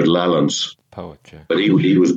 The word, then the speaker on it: Lallans: the, the, the old pronunciation and spelling of lowlands was lowlands, lowlands. Uh-huh. Actually, the Scottish language history magazine is called Lallans, L A L L A N S.